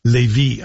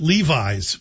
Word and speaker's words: Lévis: Living in a house full of Quebecers, I should pronounce that properly. Lévis, I think, is a better way to see it. Hey, say it. I called it Levi's for a long time.